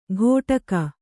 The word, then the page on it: ಕೊಡಗ: koḍaga